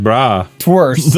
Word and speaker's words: bratwurst: Yeah, like bra. – Twurst. – The T in bratwurst is not fucking silent. – It's bratwurst.